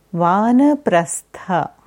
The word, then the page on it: Vanaprastha: Sanskrit